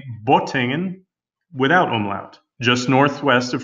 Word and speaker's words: Bottingen: His paper trail leads further back to a man with surname Matthies, who was born in a town called Bottingen, B-O-T-T-I-N-G-E-N. He spelled this without an umlaut, so it would be pronounced Bottingen.